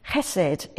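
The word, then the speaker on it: chesed (חסד): It's sometimes spelt with an H and it's sometimes with a CH because it's pronounced chesed. It's like Johann Sebastian Bach. It's that sort of ch.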